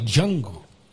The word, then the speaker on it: Django: Aunque no Diango, es Django.